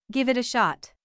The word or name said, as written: give it a shot